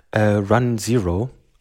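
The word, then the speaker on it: run0: Genau, und zwar hat Leonard Pattering, der Hauptentwickler von Systemd, vorgeschlagen, oder nicht nur vorgeschlagen, sondern direkt auch implementiert, Run Zero. Ich denke, so wird es ausgesprochen, also Run und Null am Ende.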